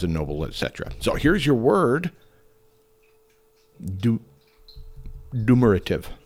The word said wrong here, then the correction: duumvirate: I'm screwing it up. It's such a unique word. Duumvirate. That's it. Duumvirate. You try to spell it. You try to do it. It's D-U-U-M-V. I-R-A-T-E, duumvirate.